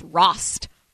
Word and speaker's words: Rost: It's Rost, R-O-S-T, but I've been called Stacey Roast multiple times,